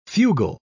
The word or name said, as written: fugal